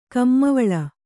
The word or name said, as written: ಕಮ್ಮವಿಲ್ಲ